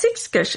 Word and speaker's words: Ishkish: That's, how do you pronounce that word? – Ishkish. – That's Ishkish, a Sasquatch, she said.